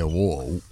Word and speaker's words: WOE: El de wow, wow. Os lo deletreo por si lo queréis buscar. A mí me ha interesado, ¿eh? W-O-E. Es que Juanfran lo pronuncia así. – Lo he dicho. W-O-E.